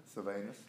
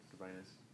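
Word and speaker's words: Silvanus: Vasudianus, am I pronouncing that right? – Sylvanus, yes. – Yeah, correct. Sylvanus.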